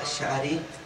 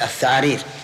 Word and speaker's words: الشعارير: أي كأنه نطق بها هكذا الثعارير يبي يقول الشعارير ولهذا